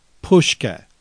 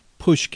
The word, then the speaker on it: pushke: Pushke, and it's spelled P as in Paul, U-S-H-K-E. Pushke.